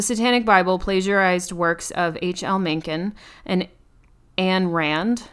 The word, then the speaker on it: Mencken: The Satanic Bible plagiarized works of H.L. Mencken. I believe that's how you say it. – Yeah, H.L. Mencken.